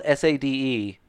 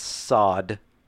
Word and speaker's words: Sade: I should say it's spelled SADE. And if you're like me and you were confused, it is pronounced sod.